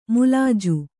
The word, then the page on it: ಮುಲಾಜು: mulāju; Kannada